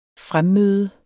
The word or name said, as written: fremmøde